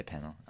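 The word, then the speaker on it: Peña: Is it Penner or? Oh yeah, my, th- Michael Penner. Yeah, Penner? I'd say Penner. – Penner.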